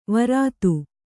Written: ವಿರೋಧ

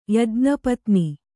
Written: ಯಜ್ಞಪತ್ನಿ

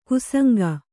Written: ಕುಸಂಗ

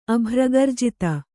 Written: ಅಭ್ರಗರ್ಜಿತ

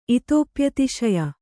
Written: ಇತೋಪ್ಯತಿಶಯ

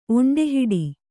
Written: ಒಂಡೆಹಿಡಿ

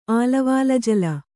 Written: ಆಲವಾಲಜಲ

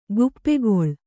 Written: ಗುಪ್ಪೆಗೊಳ್

